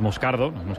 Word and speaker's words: Moscardo: Como bien ha pronunciado Unai, es Moscardo, brasileño.